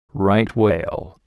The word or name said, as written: right whale